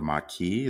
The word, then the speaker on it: Maquis: And then you have a group, I believe they're called the Maquis.